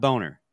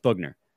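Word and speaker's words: Boughner: He joins Bob Bugner. Bugner, guys. Bugner, not Boner. Bugner. Even though it totally looks like it should be pronounced the former, but it's Bugner.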